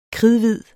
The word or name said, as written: kridhvid